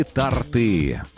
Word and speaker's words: торты: Торты или торты? – Торты. – Тортов или тортов? Как мы все-таки... – Тортов. – То есть торты в первом случае, а во втором случае тортов.